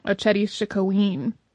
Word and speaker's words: Oceti Sakowin: Many of the indigenous people were Dakota the Dakota are part of the Oceti Sakowin, which I have also heard speakers uh, of various Dakota dialects and languages pronounce more like Oceti Sakowin.